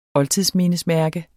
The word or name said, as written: oldtidsmindesmærke